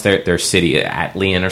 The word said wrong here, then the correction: Attilan: or something like that. – Attilan.